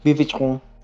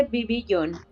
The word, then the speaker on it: Vivillon: No, va a ser vivichón. Esa es la correcta. Nada, no te creas, no, no, sé, no sé cómo se dice de verdad, pero yo también, yo también le decía sí, Vivillon, Este, no sabemos cuándo vaya a llegar, pero... Se dice Vivillon.